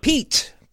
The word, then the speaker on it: Pete: Pete. Pete McMurray. – How do you spell it? – P-E-T-E. – Oh, Pete.